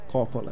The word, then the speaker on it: Coppola: Falei certo agora, né? Coppola. Coppola. Não é Coppola.